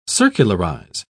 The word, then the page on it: circularise: British English